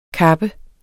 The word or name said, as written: kappes